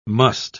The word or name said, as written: musth